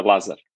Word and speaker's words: Lazar: It's Lazar.